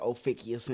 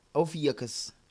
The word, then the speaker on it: Ophiuchus: Got a little bit of Ophicius in me. – I think it's like Ophicus or something. – I can't even remember how to pronounce it. That's how insignificant it is. – Ophiuchus.